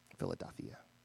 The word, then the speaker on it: Philadelphia: Or if you want to do the correct pronunciation, which I won't be doing for the rest of today, Philadelphia. Philadelphia. Yeah, that's the like Greek way of saying it. It's a Greek word.